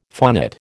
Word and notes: phonate: American English

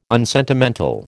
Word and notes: unsentimental: American English

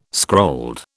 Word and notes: scrolled: British English

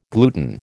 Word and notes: gluten: American English

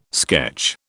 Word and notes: sketch: British English

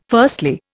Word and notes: firstly: Indian

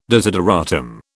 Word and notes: desideratum: British English

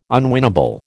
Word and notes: unwinnable: American English

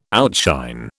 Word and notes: outshine: British English

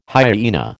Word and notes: hyena: American English

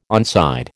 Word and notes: onside: American English

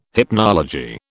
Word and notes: hypnology: British English

